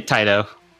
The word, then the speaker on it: Taito: And how do you pronounce that? Taito? – Taito? Yeah, I say Taito. I don't know. – Right. You say some people say Taito. – I say Taito. – Yep. That's you, Taito.